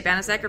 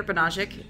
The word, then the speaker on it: Banaszek: Well, people either say Banaszek or Banaszek. It's just depending on how Polish I'm feeling that day.